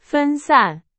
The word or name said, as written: 分散